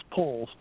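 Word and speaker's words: Powles: It's P-O-W-L-E-S, but it's pronounced Poles,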